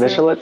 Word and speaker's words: Michelin: Mitch Tires, Michelin Tires. Yeah, so Michelin tires, Miss. – Michelin. Oh my God. Okay, this proves I cannot pronounce anything.